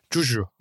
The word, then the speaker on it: cuju: S-U-J-U Juju da olabilir. Şimdi ben Kuju dedim ama İngilizce telaffuza kayarak belki. Juju diyelim. C-U-J-U. Şimdi Çincemizde olmadığı için. Juju. Juju diyelim. Daha Çince gibi geliyor yani Kuju'dan.